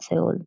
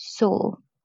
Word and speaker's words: Seoul: Sorry, not Seoul. I'm pronouncing that in Spanish. In English, Seoul. Seoul in Korea,